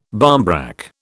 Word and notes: barmbrack: British English

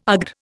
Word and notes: AGR: Indian

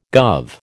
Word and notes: guv: American English

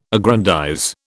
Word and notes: aggrandize: British English

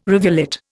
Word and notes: rivulet: Indian

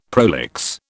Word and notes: prolix: British English

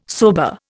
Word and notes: sober: Indian

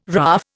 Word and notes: RAAF: Indian

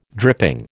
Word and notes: dripping: American English

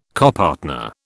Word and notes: copartner: British English

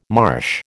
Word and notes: marsh: American English

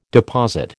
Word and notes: deposit: American English

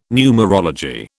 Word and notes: numerology: British English

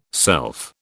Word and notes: self: British English

